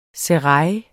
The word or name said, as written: serail